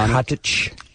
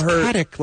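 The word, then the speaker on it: Katić: I actually know how to pronounce her name correctly. Stana Katic. She'll probably kill me for saying that. – We've been all wrong. – But I always heard it was Katic, like static.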